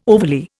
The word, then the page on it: overly: Indian